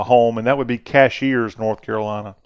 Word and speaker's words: Cashiers: Our name our town is Cashiers. – Okay. – He says we don't pronounce it like a bank cashier. But anyhow, Cashiers.